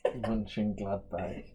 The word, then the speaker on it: Mönchengladbach: Mönchengladbach.